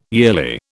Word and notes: yearly: British English

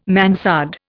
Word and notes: mansard: Indian

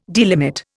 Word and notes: delimit: Indian

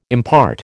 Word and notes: impart: American English

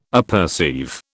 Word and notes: apperceive: British English

